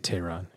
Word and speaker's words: Tehran: Do you pronounce that city Tehran? Tehran? – Oh, in Iran. I would say Tehran, yes.